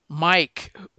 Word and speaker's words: Myke: This gave me the origin, and it opens up with Mike, which is spelled MYKE.